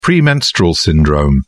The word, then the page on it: premenstrual syndrome: British English